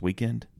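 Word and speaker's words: Weeknd: It's W-E-K-N-D or something. It's pronounced Weekend.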